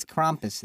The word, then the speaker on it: Krampus: It's Krampus. There's an A, not a U. It's Krampus. If we're going to do this, you got to pronounce it right.